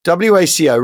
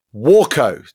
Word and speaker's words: Waco: WACO, referring to the aircraft, is usually pronounced Waco, like the first syllable pronounced as in water, not Waco, like Waco, Texas, whose name is entirely unrelated.